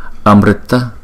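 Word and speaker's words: amṛta: Amrita amrita amrita, chữ này chúng ta đọc là ri ri ri.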